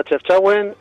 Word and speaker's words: Chefchaouen: Bueno, sí, tiene tres nombres. Sería Chefchaouen, Chaouen y Xaouen, con X.